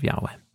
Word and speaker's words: Yahweh: Which you're saying is most likely pronounced Yahweh. – Yeah, the earliest evidence we have points in the direction of Yahweh.